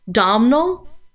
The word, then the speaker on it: Domhnall: Domhnall.